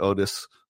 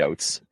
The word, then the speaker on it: Oates: So hopefully it's not Otis or something like that. – Nope, you're good. It's definitely Oates.